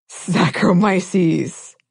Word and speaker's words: Saccharomyces: And it's usually of the genus Saccharomyces or Candida. – Yes. – I hope I got close on that pronunciation. – No, I think that was spot on.